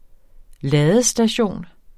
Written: ladestation

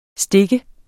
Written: stak

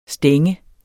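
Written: stænge

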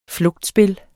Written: flugtspil

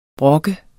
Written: brokker

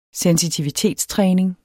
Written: sensitivitetstræning

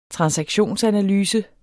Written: transaktionsanalyse

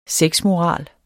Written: sexmoral

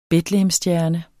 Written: betlehemsstjerne

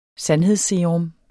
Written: sandhedsserum

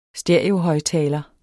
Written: stereo

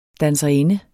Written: DANSERINDER

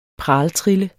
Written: praltrille

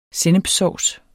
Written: sennepssovs

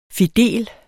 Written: fidel

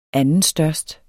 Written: andenstørst

